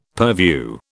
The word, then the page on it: purview: British English